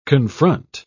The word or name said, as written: confront